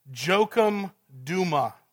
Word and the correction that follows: Jochem Douma: It's Jochem Douma, for those of you taking notes.